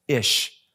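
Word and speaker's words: ish (איש): And it's pronounced, because Aleph is silent, it's pronounced ish.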